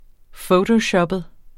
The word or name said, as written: fotoshoppet